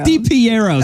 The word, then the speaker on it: DiPiero: DiPiero.